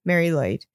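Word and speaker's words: Mari Lwyd: First, I'm going to talk about a Welsh one called Mary Lloyd. It's spelled MARILWYD, Mary Lloyd.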